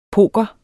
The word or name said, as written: poker